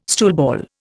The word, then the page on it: stoolball: Indian